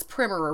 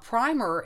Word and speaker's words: primer: Well, actually, it was primer originally. Primer is a later pronunciation. Originally, the short I was the original pronunciation of the word spelled P-R-I-M-E-R.